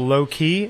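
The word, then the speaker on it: Loki: You know, there's a bar in Brooklyn called Low Key, and it's L-O-K-I.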